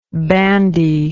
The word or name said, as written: bandiness